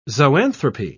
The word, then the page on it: zoanthropy: British English